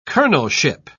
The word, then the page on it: colonelship: British English